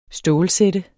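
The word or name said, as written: STÅLSÆT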